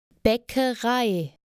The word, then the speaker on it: Bäckerei: Bäckerei. – The thing to note is that the second letter is an A with two dots over it, what they call an umlaut. Let's do a quick minimal pair for this. Imagine the letters B-A-C-K with no dots over the A.